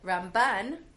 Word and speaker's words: Ramban: But Ramban, with the stress on the second syllable, actually moved to Israel. And in Israel, the way people speak Hebrew there, the accent is more likely to be put on the second syllable, as opposed to Rambam, who lived his life in Galut, where the stress is on the first syllable.